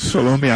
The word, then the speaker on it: Salome: Salome, I would say, yeah.